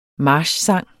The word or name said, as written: marchsang